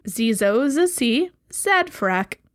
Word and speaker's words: Zezozecee Zadfrack: Zizozacy Zadfrak. – How do you spell that? – Z-E-Z-O-Z-E-C-E-E. Z-A-D-F-R-A-C-K.